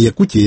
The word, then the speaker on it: Якутія: От, там наголос не Якутія, Якутія, не Якути, а Якути.